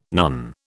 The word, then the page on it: nun: British English